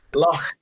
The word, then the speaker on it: Loch: Loch is pronounced like the Scottish body of water, but isn't the Scottish body of water.